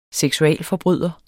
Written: seksualforbryder

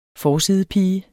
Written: forsidepige

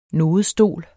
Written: nodestol

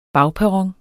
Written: bagperron

